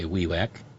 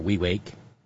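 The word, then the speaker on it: Wewak: That means thanks to Mark, working backwards, and thank you to Suman, who taught me how to say Wewek, or Wewake, instead of the mispronunciation that the entire multi-millionaire for-profit media are engaging in.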